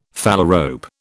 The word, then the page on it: phalarope: British English